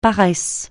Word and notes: paresse: francais

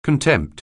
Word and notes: contempt: anglais

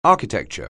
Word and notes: architecture: anglais

